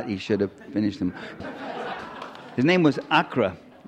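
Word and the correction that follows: Ağca: right? Is that? Asha, pronounced Asha, right.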